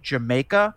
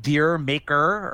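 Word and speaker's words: D'yer Mak'er: I think the other one, it took me years to figure out how to pronounce the name Jamaica, which is how you pronounce that song. I used to call it Deer Maker or whatever.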